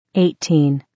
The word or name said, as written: 18